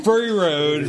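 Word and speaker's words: Fury Road: Furry Ride. – Furry Ride, yeah. – No, it's pronounced Furry Road. – Furry Road.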